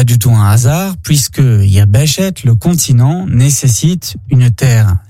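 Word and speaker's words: Yabeshet (יבשת): Il se dit en hébreu « Yabeshet ». « Yabeshet » s'écrit de la manière suivante « Yod, Bet, Shin, Tav ».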